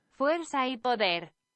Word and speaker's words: Fuerza y Poder: It is pronounced Fuerza y Poder.